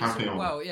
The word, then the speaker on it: Papillon: So this is Papillion. I think it's pronounced Papillion. Trailer I've recently watched. – Is it Papillon? Papillon. Is it called Papillon? – Papillon, maybe. It's Papillon.